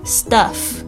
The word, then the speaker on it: stuff: 特别注意一下这个 u 的发音是发这个 a 这个倒 v 的这个声音哦， stuff stuff。